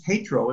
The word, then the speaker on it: Tatro: And he used to say to me, and my name is Tatro, it's pronounced Tatro, but he used to say, Mr. Tatro, you will never understand this because you are an Austrian.